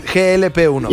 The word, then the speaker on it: GLP-1: Les vamos a hablar de las inyecciones GLP-1. ¿Lo dije bien? GLP-1.